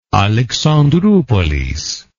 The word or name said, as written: Alexandroupolis